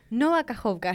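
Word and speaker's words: Nova Kajovka (Нова Каховка): Pero, de alguna manera, la inundación, la, explosión de la represa de Nova Kajovka. – ¿Cómo? – Nova Kajovka. – Nova Kajovka.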